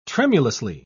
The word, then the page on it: tremulously: British English